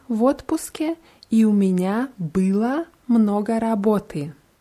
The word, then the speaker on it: было: Bei была ist die ganz deutlich auf a und у меня было много работы. Было ist auf ü, weil äh, sonst, wenn man было auch auf die letzten Silbe betont, dann hört es sich wie была an. Было, была.